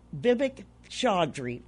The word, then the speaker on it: Vivek Chaudry: Joining me on the live line, it's Dr. Vivek Chaudry.